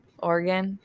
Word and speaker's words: Oregon: Oregon. Yeah, Oregon is how we say it in Michigan, but it's totally wrong. – It's because of our accent. We were taught wrong in schools.